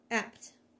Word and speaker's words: Abt: but the last name is pronounced apt. It's apt Franz, or Franz Abt.